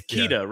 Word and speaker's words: Keita: It's Keita, right? – Yeah, it's Keita. I'm calling him Keita. So okay, I'm calling Keita, but it's like it's like Keita. Yeah. It's like keto diet. It's Keita.